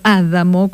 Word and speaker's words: Adamo: Escribe Claudio Castro Adamo, con el acento en la primera A. Yo no lo conozco personalmente, Gabriel Adamo. En italiano lo digo Adamo. Si alguno me quiere corregir, bienvenida a esa corrección. Pero en italiano para mí se pronuncia Adamo.